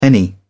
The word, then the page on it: any: British English